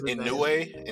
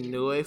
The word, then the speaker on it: Inoue: Inu? Is that how you pronounce it? – I think it's Inoue. – Inoue? – Yeah. – Okay, Inoue for sure.